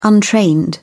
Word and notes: untrained: British English

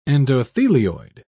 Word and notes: endothelioid: British English

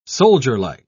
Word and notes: soldierlike: British English

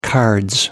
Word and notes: cards: British English

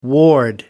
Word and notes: ward: American English

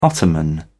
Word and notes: ottoman: British English

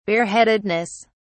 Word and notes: bareheadedness: British English

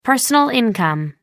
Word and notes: personal income: American English

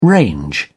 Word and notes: range: British English

